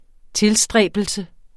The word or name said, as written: tilstræbelse